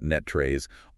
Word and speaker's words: NTrace: And for the locals listening, we're pronouncing that NetTrace, honoring the North Texas tradition.